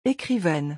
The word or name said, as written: ecrivaine